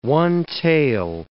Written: one-tail

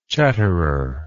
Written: chatterer

